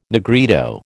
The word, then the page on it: Negrito: American English